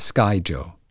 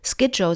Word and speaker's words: Skyjo: Also die Aussprache von Skyo ist nicht eindeutig festgelegt, aber die häufigste und wohl auch vom Verlag empfohlene Variante ist Skyjo.